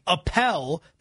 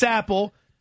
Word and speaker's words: Apple: We're not just going to start calling the computer company down the street Appel because we feel that that's a better way to pronounce it. It's Apple.